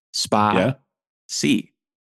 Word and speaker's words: Spatie: I know how that's pronounced because there was just a tweet where it was two words and there were pictures. Spa-C.